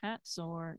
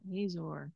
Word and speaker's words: Hazor: And our guide made sure that we knew that it's pronounced Hazor, not Hazor. Our guide was a native Israeli.